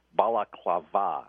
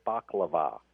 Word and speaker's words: Balaklava: Balaklava. And I made sure I pronounced that correctly, Chuck, because I always tend to say baklava, which is a food, right? – And I think.